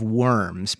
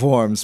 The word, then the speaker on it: Worms: This last week, we celebrated the 500th anniversary of the, now it looks like Diet of Worms, but that's not how we say it, right? How do we say it? – The Diet of Worms, but Worms.